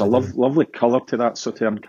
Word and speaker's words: Sauternes: Is it Sauternes? – Sauternes. – Oh, there you go. Sauternes.